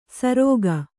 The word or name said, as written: ಸರೋಗ